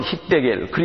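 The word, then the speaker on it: 히떼겔 (חידקל): 히브리어로는 히떼겔, 그리스 말로는 티그리스. 영어 성경 책에는 티그리스라고 나와 있는 성경도 있습니다. 제가 보고 있는 중국어 성경에는 띠거리스 그렇게 나와 있어요. 중국어로 발음으로 읽으면. 예. 여기 나와 있는 유프라테스도 그리스 말로는 유프라테스. 히브리 말로는 유브라데. 발음이 약간 다르죠.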